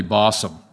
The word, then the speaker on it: Bossom: His name is Randy Bossom. Rhymes with possum, for those of you country folks that understand.